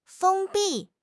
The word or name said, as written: 封闭